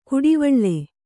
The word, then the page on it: ಕುಡಿವಳ್ಳೆ: kannada